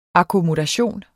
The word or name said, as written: akkommodation